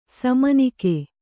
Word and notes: ಸಾಮಾನಿಕ: sāmānika

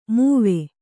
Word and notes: ಮಾವಿ: māvi

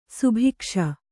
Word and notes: ಸುಭಿಕ್ಷ: subhikṣa

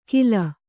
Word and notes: ಖಿಲ: Kannada

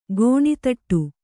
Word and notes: ಕಣ್ತಡೆ: kannada

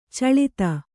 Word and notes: ಚೀಲೈತ: cīlaita

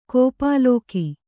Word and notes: ಗೋಪಾಲಕ: gōpālaka

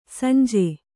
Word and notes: ಸಂಜೆ: sanje

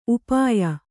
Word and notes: ಉಪೇಯ: upēya

